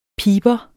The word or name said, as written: piber